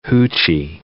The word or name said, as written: hoochie